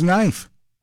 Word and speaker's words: knife: Then there's knife. The K is silent. Same as night. The K isn't doing any work in those words.